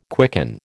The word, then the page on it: quicken: American English